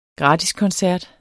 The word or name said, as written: gratiskoncert